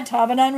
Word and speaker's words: Tabanan: The Bali Silent Retreat in Tabanan, is that how we pronounce it here? – Yeah, Tabanan Regency.